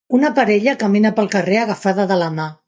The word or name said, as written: agafada